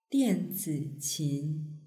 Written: dianziqin

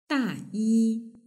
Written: dayi

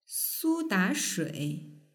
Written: sudashui

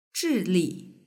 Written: zhili

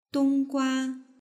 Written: donggua

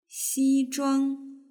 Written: xizhuang

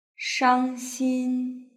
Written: shangxin

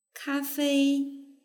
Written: kafei

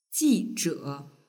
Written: jizhe

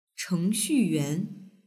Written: chengxuyuan

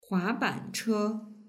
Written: huabanche

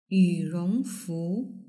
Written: yurongfu

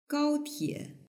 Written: gaotie

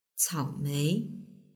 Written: caomei

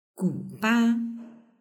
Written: guba

